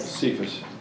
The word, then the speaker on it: Cephas: Is it Caiaphas or Cephas? – Cephas. – Caiaphas would be the high priest.